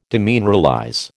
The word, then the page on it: demineralize: American English